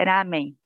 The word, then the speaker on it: ramen: mas o certo lá é ramen, né. Ah, então, né, é, quanto, quanto à pronúncia, né, é, para nós brasileiros, ela é difícil, porque quando começa com R no início, para a gente é ra, então eu escuto muita gente falar ramen,